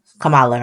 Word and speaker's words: Kamala: Kamala, right? That's how you pronounce her name.